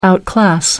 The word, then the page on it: outclass: American English